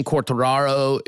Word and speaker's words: Quartararo: You are very, very much, that is a common pronunciation. Quartararo, I think, is closer to his home French language.